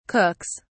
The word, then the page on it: cooks: British English